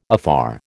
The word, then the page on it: afar: American English